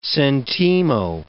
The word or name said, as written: sentimo